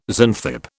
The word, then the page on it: Xanthippe: British English